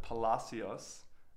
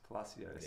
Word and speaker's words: Palacios: I'm joined by Robert Palacios, which I say like an Italian, but I know it's not a, it's a more Spanish close.